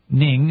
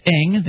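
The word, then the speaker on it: Ng: Meanwhile, his sister said Mr. Ning, who's actually pronounced Eng,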